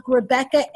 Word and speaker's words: Rebekah: And so if you do follow me on Facebook, Rebecca A. Sharp, and my name is spelled the biblical way, R-E-B-E-K-A-H.